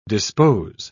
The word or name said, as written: dispose